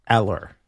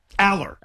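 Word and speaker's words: Allar: It's on Pro Football Reference, Aller. – Aller. – Aller. – So it's neither.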